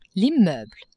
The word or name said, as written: immeuble